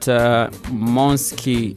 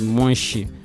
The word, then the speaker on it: Monski: So, there's a time I was told that Monski, it's written as Monski, but it's supposed to be pronounced as Monshi.